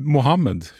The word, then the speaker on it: Mohammed: Jag har förstått så kan du inte klassisk arabiska, men hur skulle man, Mohammed, hur skulle man uttala det som mest korrekt, tror du? – Det beror ju på vem som har korrekt, men klassiskt det är Mohammed.